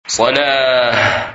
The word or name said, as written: Salah